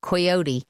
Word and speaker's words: coyote: And switching gears completely, Coyote versus Acme. Do you say coyote? – We say coyote. – Coyote? – Yeah. – Even though it's spelt with an O.